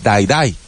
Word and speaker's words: Dai Dai: Y viste que Shakira ahí sacó una cochinada de canción que se llama Dai Dai, que es la misma pronunciación de Dai Dai en inglés y significa muere, muere.